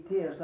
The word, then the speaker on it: tierce: Test, T-I-E-R-C-E, test. I reckon that's where you pronounce it.